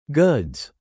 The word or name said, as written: goods